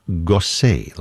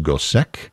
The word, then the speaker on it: Gossec: Last name is spelled G-O-S-S-E-C. You might think that would be pronounced Gosek, but we know that he himself pronounced it that way because he would spell his name differently, sometimes with an E-Z on the end, an E-T, whatever it is.